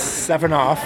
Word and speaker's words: Sevenoff: Oh, my last name is Sevenoff, S-E-V-E-N-O-F-F, but my friends call me Sevi as a nickname.